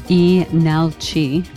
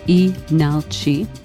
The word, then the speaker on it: Leenalchi: We just heard from the South Korean band Enalchi, actually spelled L-E-E-N-A-L-C-H-I, but the first L is silent, Enalchi, from their EP, Here Comes That Crow.